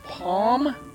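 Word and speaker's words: Pomme: Artist whose name I will pronounce Palm. That's P-O-M-M-E.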